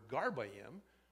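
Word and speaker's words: garbayim (גרביים): So in Hebrew, if you were talking about a pair of socks, for instance, you would take the word for sock, which phonetically is garb, G-A-R-B, which is interesting because we sometimes call our clothing that, and you would add I-M, A-Y-I-M, to it to make the word garbayim, or in other words, a pair of socks.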